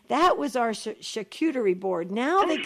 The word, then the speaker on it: charcuterie: how did you say it? Charcuterie, or how did they say it? – That's charcuterie.